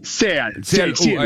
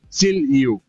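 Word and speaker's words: Seal U: Se escribe Seal, sí. ¿No? Seal-U. – Seal-U, ahí está. Seal y la letra U. Seal-U.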